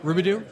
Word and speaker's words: Roubidoux: Roubidoux.